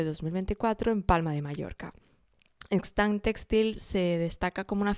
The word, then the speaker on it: Xtant: Luego tenemos la feria Extant, eh, que se escribe X-T-A-N-T, pero bueno, todo esto estará en el PDF este que os podéis descargar.